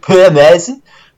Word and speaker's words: Peter Madsen: Yeah, my last contribution is a series of comic books by actually a Danish author called Peter Madsen, or as the Danes would pronounce it, Per Madsen.